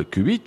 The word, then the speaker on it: qubit: On nomme qubit la contraction de quantum plus bit, on prononce en anglais qubit, l'état quantique qui représente l'unité de stockage d'informations quantiques.